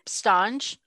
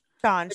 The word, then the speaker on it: Stange: It's actually stange. – Stange. – Which I will also say, I know that I have told people the incorrect way to pronounce it. So anybody who's hearing me now, know that I now know that it's pronounced stange, and I have done you a disservice.